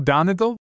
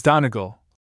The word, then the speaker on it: Donegal: Do you know Donegal? Asked I of the waiter, giving to my pronunciation of the word a long second and a short third syllable.